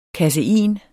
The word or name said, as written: kasein